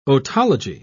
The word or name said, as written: otology